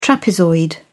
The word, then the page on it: trapezoid: British English